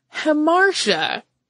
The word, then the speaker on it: hamartia: And we were all mortified because we had learned the wrong pronunciation from our, you know, bona fide PhD in English instructor. Years and years and years, almost 20 years later, I was telling Holly the story and I went to the dictionary to look up the word. And what was there? Hemartia as the pronunciation.